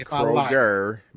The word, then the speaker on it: Kroger: I will not allow you to do the Detroit pronunciation of Kroger with the S on it. – I will add the S like Myers. – No, it's Kroger, Meyer. You don't put the S on there.